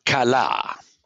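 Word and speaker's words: kala (כלה): And the verb is kala, K and an L and a He, which is not pronounced.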